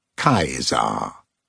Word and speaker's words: Kaiser: The boys of the lower school call him Kaiser, pronouncing the C as a K, like the Latin teacher taught them. Kaiser.